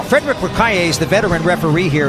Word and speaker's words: L'Écuyer: L'écuyer. – Did I pronounce that wrong? – You know, you got it right. You got it good.